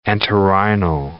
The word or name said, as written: entorhinal